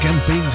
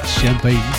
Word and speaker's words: Champagne: En zo spreek je het uit. Het kan ook campaign zijn, maar het is toch echt Champagne.